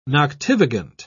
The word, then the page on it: noctivagant: British English